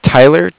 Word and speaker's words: Tyler: Yeah, my name is Tyler, T-Y-L-E-R. – Okay. – And I'm calling with Cardiff. It's spelled C-A-R-D-I-C-A-R.